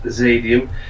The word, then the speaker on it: Xadium: Their name on Twitter is it's spelled XADIUM, pronounced Zadium.